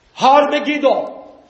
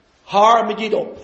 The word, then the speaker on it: Har Megiddo: Revelation 16:16 says that they gather together in a place called in the Hebrew tongue, Har Megiddo, That's how you say it in Hebrew. Har, Hebrew for hill, Megiddo. Har Megiddo, the hill of Megiddo.